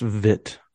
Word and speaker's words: Witt: I think that's the Bobby Witt draft. I don't know. Only pitcher I can think of. He's not NL, so that's not going to help. – It's pronounced Vit. – Oh, Bobby Vit.